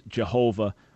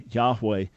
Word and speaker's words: Yahweh: There's no such name as Jehovah or Yehovah. He was never called by that name, but rather Yahweh, from everything we understand, is the best pronunciation of the name of the God of Israel.